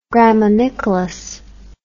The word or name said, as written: graminicolous